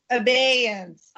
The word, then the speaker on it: Abeyance: Abeyance, did I say it right? – Abeyance. – Abeyance.